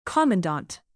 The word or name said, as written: commandant